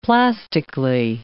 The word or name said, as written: plastically